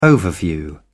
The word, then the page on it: overview: British English